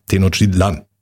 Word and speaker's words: Tenochtitlan: Der Apostroph ist auf, oder der, die Betonung liegt auf dem letzten A. Tenochtitlan.